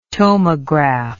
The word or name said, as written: tomograph